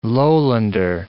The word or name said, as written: lowlander